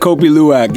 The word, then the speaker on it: Kopi Luwak: Talking about coffee, so have you ever had, and I'm going to probably butcher the name, but I've had it, Kopi Luwak. – Kopi Luwak, yeah.